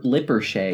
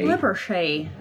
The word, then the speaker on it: Lippershey: Hans Lippershey, I hope I said that right, invented the telescope. – Lippershey. – Lippershey.